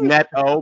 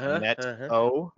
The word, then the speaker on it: Neto: ding, ding, it is Neto, by the way, because I got my fancy pronunciation list. So it's Neto, like the net and then a no.